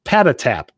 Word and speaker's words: Patatap: It's called Pad App, Patatap. Sorry, I pronounced I missed a at in there. Patatap, and you do need the sound on for this.